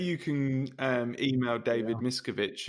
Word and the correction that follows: Miscavige: I believe it's pronounced Miscavige, and I'm not about to get into a lawsuit.